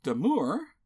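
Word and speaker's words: Domergue: Faith Damour, that's how IMDb says she pronounced it at least,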